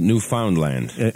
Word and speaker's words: Newfoundland: Well, is it Newfoundland or is it Newfoundland? – I think, really, it's Newfoundland, you know. – But somebody once told me it rhymes with understand. Newfoundland, understand.